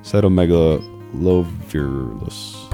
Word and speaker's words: cytomegalovirus: Cytomegalovirus.